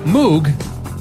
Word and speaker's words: Moog: Moog is how you spell it. So same as Andy Moog. – Right. – But we call it the pronunciation. The guy who created it, his last name is Moog as well, but he would just pronounce it as Moog.